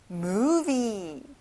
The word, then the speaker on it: movie: 영화, movie. 자, 가운데 V가 있죠? 앞니를 입술에 닿게 해보세요. Movie. That's right.